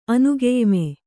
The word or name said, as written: ಅನುಗೆಯ್ಮೆ